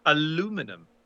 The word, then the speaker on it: aluminum: Oh, aluminum. There's another one, of course. Aluminium. That's a different thing entirely. Of course, we just pronounce it differently.